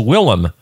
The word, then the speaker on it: Willem: William Dafoe. No, I said it wrong. It's Willem. Look at that. See? Willem. W-I-L-L-E-M.